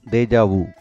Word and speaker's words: déjà vu: The word is pronounced déjà vu.